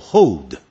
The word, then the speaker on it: hold: When you hold my hand，当你呢握住我的手，这个 H-O-L-D 有多少人会念错，会念成 hold，记住它是不是有个 L，所以因此呢你念的叫 hold，舌尖要抵上去再念一个的 hold，但那个的轻轻点到就好了。